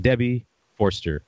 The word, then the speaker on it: Debbie Forster: 20 odd questions with Debbie Forster.